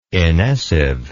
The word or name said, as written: inessive